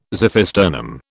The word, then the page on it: xiphisternum: British English